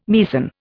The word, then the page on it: Meissen: Indian